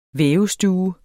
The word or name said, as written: vævestue